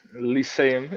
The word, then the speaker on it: Lyceum: I know that you played for I'm going to try my best to pronounce the name, but it's Cartesius Lycum. Is that right? – Cartesius. – Am I close? – Lyceum. Yeah, you're somewhat close.